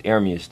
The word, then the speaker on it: Ermias: It's Ermias, though. That's how you say it.